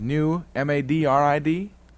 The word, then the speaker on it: New Madrid: M-A-D. – Oh, Madrid. – Yeah, no, that's New Madrid. – New Madrid. – Yes, sir. And you've got to say it right or you're in trouble.